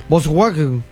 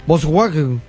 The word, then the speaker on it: Volkswagen: Volkswagen. – Sí, algunos dicen Volkswagen.